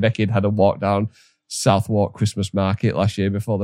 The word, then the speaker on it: Southwark: Southwark, yeah. So there's apparently, again, Northern. Apparently there's a place in London called Southwark, but they spell it Southwark in a bid to confuse Northerners, I assume.